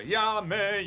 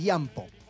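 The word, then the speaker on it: Jamme: Llame. – No. Pero no es llame, es Yampo.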